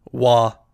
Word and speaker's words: Roy: My notes say Patrick Roy, so that is how I'm pronouncing it. – It's spelled, but it is pronounced Wah.